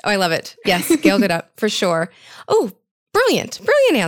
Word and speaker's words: Gal Gadot: Okay, I would choose Gal Gadot.